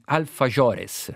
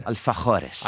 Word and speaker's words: alfajores: Proprio per tutto, per chi ha inventato gli alfajores. – Alfajores. – Alfajores. Lui è qui solo per correggermi. – Pronuncia spagnolo.